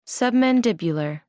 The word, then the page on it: submandibular: American English